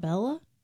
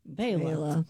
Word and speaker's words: Bela: Born in 1958, the great banjo player, Mr. Fleck, first name B-E-L-A. – B-E-L-A. It's not Bella? – It's Bela. – Bela.